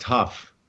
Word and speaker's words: Hough: It's actually pronounced Huff.